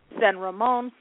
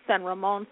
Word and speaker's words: San Ramon: It's actually pronounced in the Hispanic culture, in Spanish, San Ramon, San Ramon, San Ramon. So that's St. Raymond, St. Raymond, St. Raymond in English.